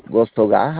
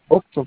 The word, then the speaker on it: Gostogás: ah, okay, Es Gostogás, algo así me dice. Mira, se la voy a deletrear para que. Sí, Gostogás. – Oxogas. – Es como si se pronunciara Octo, pues, pero es Gosto.